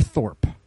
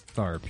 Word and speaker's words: Tharpe: Tharpe or Thorpe? – Well, it's with an A, but it could be pronounced differently. I'm not good at pronunciation. – Then it's Tharpe, yeah.